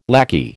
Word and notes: lackey: American English

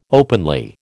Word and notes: openly: American English

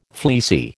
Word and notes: fleecy: American English

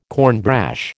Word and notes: cornbrash: American English

